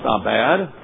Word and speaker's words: abad: Now, the two Hebrew words for cultivate and keep, one is pronounced abad for cultivate, or you could translate work it, and in shamer to guard it.